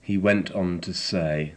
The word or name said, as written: went on to say